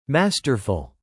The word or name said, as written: masterful